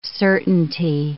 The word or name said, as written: certainty